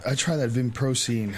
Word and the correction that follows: vinpocetine: How do you say it? – There's, yeah. So I'm, I think I'm, I might make, this is vinpocetine.